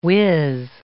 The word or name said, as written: whizz